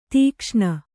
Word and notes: ದಕ್ಷಿಣ: dakṣiṇa

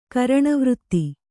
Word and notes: ಕರಣವೃತ್ತಿ: Kannada

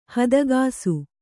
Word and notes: ಹದುಗಿಸು: hadugisu

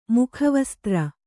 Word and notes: ಮುಖವಸ್ತ್ರ: kannada

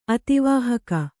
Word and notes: ಅತಿವಾಹಕ: ativāhaka

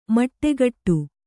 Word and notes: ಮಟ್ಟೆಗಟ್ಟು: maṭṭegaṭṭu

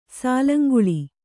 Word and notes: ಸಾಲಂಗುಳಿ: sālanguḷi

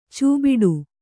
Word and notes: ಛೂಬಿಡು: chū biḍu

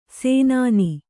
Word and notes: ಸೇನಾನಿ: sēnāni

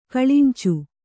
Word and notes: ಕಲ್ಲಂಚಿ: kallanci

